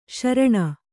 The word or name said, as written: ಶ್ರಾಣ